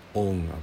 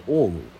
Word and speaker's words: オン: んもとおどと困っています助けてくださいお願いします如来様お願いします仏様お願いしますオンアビランケンオンアビランケンオンってこれオウムですねヒンズ教でオウムを発音が、まあ、日本語訳でオンになって